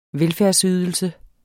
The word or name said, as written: velfærdsydelse